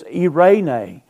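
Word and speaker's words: eirene (εἰρήνη): You don't care about the word, how to pronounce it, but it's eirene.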